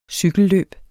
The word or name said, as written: cykelløb